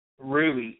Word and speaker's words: Ruey: You can find me on Facebook underneath Jesus, spelled like Jesus, Ruey, R-U-E-Y, Mitchell, with two L's, on Facebook. Jesus, spelled like Jesus, middle name, Ruey, R-U-E-Y, last name, Mitchell, with two L's.